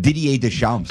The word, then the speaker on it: Deschamps: How do you say that? – Didier Deschamps. – Deschamps.